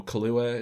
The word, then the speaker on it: Kaluuya: daniel kaluuya is how you say it, mm-hmm.